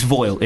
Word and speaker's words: voile: But I'm actually just trying to find the pronunciation of this shit. It's voile. It is voile.